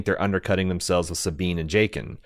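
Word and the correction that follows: Jacen: Or Jason? It's Jason. I always pronounced it Jaqen when I was reading it growing up, but I guess it's Jason. Sabine appears to be in her late teens,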